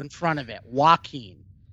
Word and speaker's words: Joaquin: Not Joaquin. Joaquin.